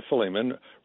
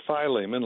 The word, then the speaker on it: Philemon: Now, you can pronounce it Philemon like we do here in Texas, but if you want to be a little more Greekish, or should I say Grecian, you could probably pronounce it the way it should be pronounced in Greek, which is Philemon.